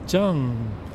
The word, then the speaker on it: Tsang: As opposed to myself making a fuss about how my name should be pronounced, because there's the romanization, T-S-A-N-G, but that's not how you say it. I mean, it's not English, so it's Zheng.